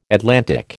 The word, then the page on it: Atlantic: American English